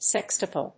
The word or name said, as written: sextuple